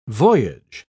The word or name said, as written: voyage